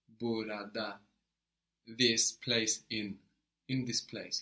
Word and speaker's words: burada: The word for here is burada. – Burada. – But in the spoken language, you'll often hear burada. Burada. So it's spelled burada, Burada, but it's often shortened in spoken to burada, burada.